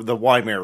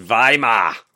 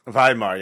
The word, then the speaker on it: Weimar: The Weimar Republic period, not 10 years later. – I believe it's pronounced Weimar. – Weimar, yeah, sorry.